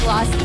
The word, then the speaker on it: Los: Jamie Lee Curtis and Mariska Hargitay got into a playful argument over how to pronounce Los Angeles. Jamie pronounces it Los, like it rhymes with gross.